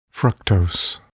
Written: fructose

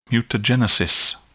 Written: mutagenesis

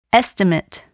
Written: estimate

